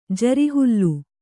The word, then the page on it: ಜರಿಹುಲ್ಲು: Kannada